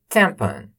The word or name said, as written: tampon